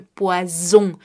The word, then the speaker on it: poison: Así que cuidado al pronunciar esta palabra que será le poison, le poison.